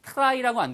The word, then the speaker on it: try: 이거 발음이 try라고 안 들리고요. 추추추. 추추. 이게 기차 갈때 나는 소리. try처럼 들립니다.